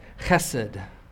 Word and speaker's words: Chesed: It is the Hebrew word Chesed, and you got to spit a little bit to say it right. Chesed.